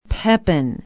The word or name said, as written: Pepin